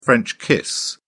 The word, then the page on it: french kiss: British English